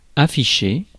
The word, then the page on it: afficher: France; [a.fi.ʃe]